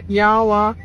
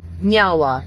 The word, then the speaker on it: nyawa: So in our language, we say nyawa, which means thank you. – Nyawa. – Nyawa. – I said it right? – Yeah, you did.